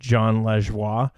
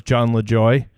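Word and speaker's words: Jon Lajoie: Like, I enjoy his stuff, but I didn't know that his name was actually pronounced John Lajoie. I thought it was John LaJoy.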